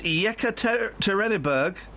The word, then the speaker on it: Yekaterinburg: It's Yekaterinburg. – Oh, that's brilliant! I used to have a friend that worked in Yekaterinburg. – Yekaterinburg, you idiot! – No, I'm not an idiot. Yekaterinburg.